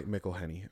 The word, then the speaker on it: McElhenney: McElhenney, I think. McElhenney? McElhenney? – Okay. McElhenney. Okay.